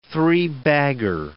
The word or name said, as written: three-bagger